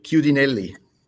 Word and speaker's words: Chiudinelli: I love the anecdotes of some of the kids that managed to beat Roger, such as Marco Chiudelli. Am I saying that right? – Chiudinelli. – Goodinelli, thank you.